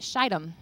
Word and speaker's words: Shittim: And if you've ever heard my husband preach on this passage, in the young adults, he uses a little bit of a different pronunciation of this word. But we're in main service, and we're going to be holy, and we're going to pronounce it Shittim.